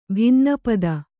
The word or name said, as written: ಭಿನ್ನಪದ